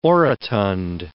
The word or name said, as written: orotund